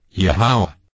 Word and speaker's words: Yahweh: Yahweh's name is pronounced using three of these vowels, Yod He Wa He vocalized, Yahweh.